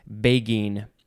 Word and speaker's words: beguine: I always get confused about the G and G, G, J sound, but I think this one is begine. It is spelled B-E-G-U-I-N-E.